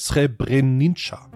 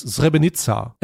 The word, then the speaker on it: Srebrenica: Oder ich brauche nur endlich Srebrenica. Ich glaube, so spricht man es aus.